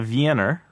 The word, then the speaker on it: Wiener: And then somebody who is a citizen of W-I-E-N is a, and it is pronounced with a V, even though it's spelled with a W, right? – Right. – It is a Wiener, right?